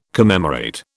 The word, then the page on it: commemorate: British English